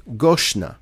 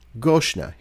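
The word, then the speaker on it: Goshna (גשנה): It's spelled Gimel Shin Nun He, pronounced Goshna. Goshna.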